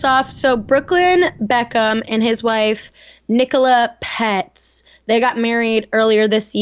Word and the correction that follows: Peltz: So he's now Brooklyn Pets Beckham. Peltz. Peltz. – I've been saying that wrong.